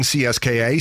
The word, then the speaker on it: CSKA: It's pronounced CSKA. – CSKA. – But there's like a CSKA. – Oh, like a T in it.